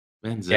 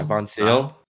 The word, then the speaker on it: Ivan van Zyl: I mean, like, what, it was Elliot Daly's try into Ivan Van Zyl? Is that how you say it? – Van Zyl? Yeah, Van Zyl.